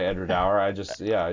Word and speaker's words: Edradour: The tour guide said it's Edradour as in flower.